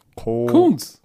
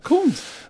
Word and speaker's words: Koonce: K-O-O-N-C-E. Wie spricht man das aus? – Kunz. – Kunz, okay. – Kunz. – So, Malcolm Kunz. – Doppel-O ist doch U in Englisch.